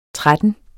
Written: tretten

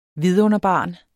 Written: vidunderbarn

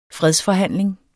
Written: fredsforhandling

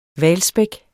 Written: hvalspæk

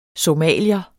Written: somalier

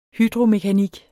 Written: hydromekanik

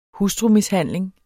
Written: hustrumishandling

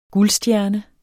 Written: guldstjerne

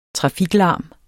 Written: trafiklarm

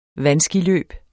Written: vandskiløb

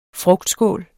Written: frugtskål

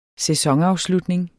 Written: sæsonafslutning

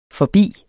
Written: forbi

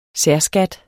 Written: særskat

